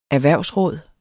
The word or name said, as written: erhvervsråd